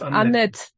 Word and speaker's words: Annette: Genannt, genannt Annette. Annette. Annette. – Genau. – Das e genau. E spricht man auch nicht.